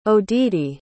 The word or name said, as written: odede